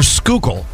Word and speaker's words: Schuylkill: Is it pronounced Schuylkill or Schuylkill? – Schuylkill.